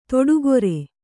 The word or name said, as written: ದಿಟ್ಟಗಾರ